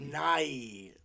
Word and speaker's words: Nighy: Because his last name is spelled, like, Nighty, but without a T, like, mm-hmm. N-I-G-H-Y. – Yeah, Nahi. – So it's like, Nye. Like, Night, I don't know. I'm fucking saying it. I'm sure I'm saying it wrong.